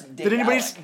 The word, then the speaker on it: Daedalic: I believe that's Daedalic. – Did anybody see? No, they told me it was Daedalic. – Oh my god, I've been mispronouncing it.